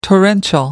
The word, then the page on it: torrential: American English